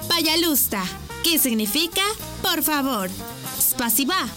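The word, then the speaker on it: пожалуйста: Y se dice payalusta. ¿Cómo? Payalusta. Una vez más. Payalusta. Y se escribe payalusta. P-A-Y-A-L-U-S-T-A.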